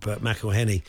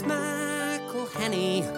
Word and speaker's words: McElhenney: It's McElhenney, McElhenney. What ways to massacre and mispronounce it, there are many. It's McElhenney, McElhenney. If there was doubt, now there won't be any. It's McElhenney.